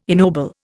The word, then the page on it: ennoble: Indian